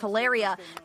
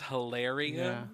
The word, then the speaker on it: Hilaria: And her name is Hilaria? – Yeah. – It rhymes with malaria.